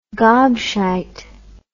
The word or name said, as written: gobshite